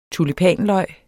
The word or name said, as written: tulipanløg